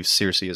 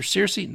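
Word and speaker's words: Cercy: I believe that's how you say it. It's C-E-R-C-Y. I believe Searcy is how you say it.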